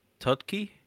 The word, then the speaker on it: Tudyk: Alan Tudyk, K2SO. Yeah. – Spell it for me, please. His last name. – Tudyk.